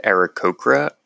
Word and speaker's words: Aarakocra: Editor's note, I do know that the correct pronunciation is Aarakocra.